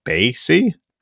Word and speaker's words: BAYC: I know the acronym is B A Y C, but you actually pronounce it BASY.